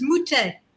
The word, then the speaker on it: Moutet: No, incidentally, it is Moutet. – Moutet. – Yeah, how did I not know that? – Yeah, the T is not pronounced.